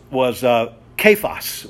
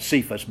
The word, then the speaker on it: Cephas: So Peter's name also was Kephas. We call it Cephas, but they didn't pronounce a C like we did. They pronounced it as a hard K.